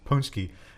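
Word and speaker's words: pączki: Polish donuts, Punchki. It's not spelled anything what it sounds like.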